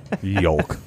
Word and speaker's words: yolk: Yolk. – Yolk? You pronounce the L? – That's how it's spelled. – Yolk. – So? Yolk. – It's yolk. Yolk's on you, right? – Stop looking at me.